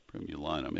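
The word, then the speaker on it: Jasminum primulinum: Jasminum is JASMINUM, and primulum is PRIMULUM. Primulinum. It may be linum. Primulinum. I think there's an extra syllable in there.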